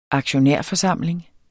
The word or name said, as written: aktionærforsamling